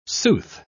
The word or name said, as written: sooth